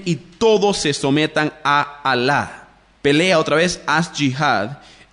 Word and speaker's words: Alá: Ahora, cuando usted escucha la palabra Alá, A-L-A, con acento en la segunda A, eso es su Dios, ¿bien?